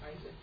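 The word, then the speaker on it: Isaac (Ἰσαάκ): How do you say Isaac in Greek? – Isaac.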